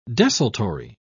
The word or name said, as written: desultory